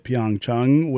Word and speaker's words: Pyeongchang: Tonight, we will visit Pyeongchang with Devin at 8 p.m.